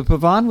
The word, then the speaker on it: pavane: Put it in English, and it's chaconne, and it's spelled C-H-A-C-O-N-N-Y. – Right. – So pavane is spelled in English P-A-V-A-N, and it's pronounced pavan. Pavan.